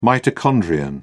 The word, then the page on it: mitochondrion: British English